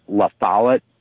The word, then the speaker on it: La Follette: Oh, yeah, and I was going to say, it's pronounced La Follette, Bob La Follette.